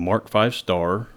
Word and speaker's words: Mark V Star: And that asterisk in early British tank designations was usually pronounced as a star when spoken.